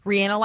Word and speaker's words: Rihanna: Rihanna like banana.